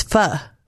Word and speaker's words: pho: opened it up and I came upon is it pho? – It's pho.